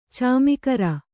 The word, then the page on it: ಚಮಗಾರ: camagāra